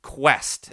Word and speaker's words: KWESST: In this daily editorial, I am introducing Quest. That is spelled K-W-E-S-S-T to all of you, our listeners.